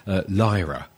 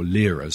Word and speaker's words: Lyra: Well, for August, my constellation of the month is Lyra, or Lyra, some people pronounce it, L-Y-R-A.